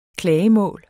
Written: klagemål

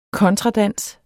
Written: kontradans

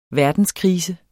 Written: verdenskrise

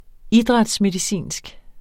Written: idrætsmedicinsk

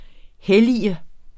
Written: hellige

